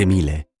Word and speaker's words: Miele: Si scrive Miele, si legge Miele.